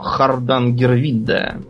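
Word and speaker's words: Hardangervidda: Они вместо этого почему-то понеслись э, не куда-нибудь, а вовсе даже к заводу по изготовлению тяжелой воды на плато Хардангервидда Поправь меня, если я неправильно произношу. – Ну, как-то так, да, да.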